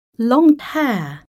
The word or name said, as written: long hair